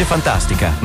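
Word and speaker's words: Funtastica: Fantastica, si legge Fantastica, ma si scrive con la U.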